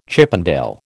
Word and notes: Chippendale: American English

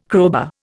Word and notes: crowbar: Indian

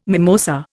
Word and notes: mimosa: Indian

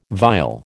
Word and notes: vile: American English

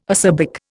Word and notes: acerbic: Indian

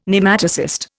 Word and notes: Nematocyst: Indian

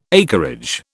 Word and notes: acreage: British English

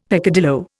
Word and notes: peccadillo: Indian

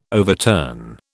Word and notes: overturn: British English

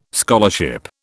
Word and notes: scholarship: British English

